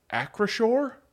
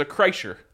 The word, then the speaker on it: Acrisure: Yes, I learned how to say this today. Acrisure? – There you go. There you go. – This whole time I thought it was a Kreischer.